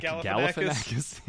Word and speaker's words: Galifianakis: Zalifianakis and Clooney. – Zalifianakis. – How do you say his name? – Zach Galifianakis. – Zalifianakis.